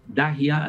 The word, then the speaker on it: Dahiya: Dahia, así se escribe, D-A-H-I-Y-A.